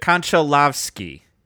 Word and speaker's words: Konchalovsky: Konchalovsky.